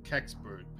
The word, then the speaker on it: Kecksburg: I think it's called Kecksburg, is how you pronounce it. Kecksburg? Kecksburg. Oh. It's it's kind of wild. And now we're going to flash forward to 1965, Kecksburg, Pennsylvania.